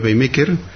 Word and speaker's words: PageMaker: Es un paquete de autoedición para sistemas GNU Linux al estilo de programas comerciales como el Adobe PageMaker.